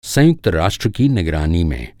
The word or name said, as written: निगरानी